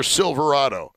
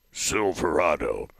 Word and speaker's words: Silverado: Because at some point, even an invisible Sasquatch would be hit by somebody's Ford pickup truck or Silverado. Wait, I didn't say that right. Silverado. Thank you. That's much better.